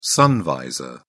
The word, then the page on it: sun visor: British English